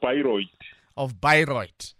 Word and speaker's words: Bayreuth: Prof, you will help me pronounce that university you are stationed at the moment in Germany. Last I knew you were in UNISA, and then now, Prof, who's in Germany? – University of Bayreuth.